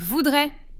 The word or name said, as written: voudrais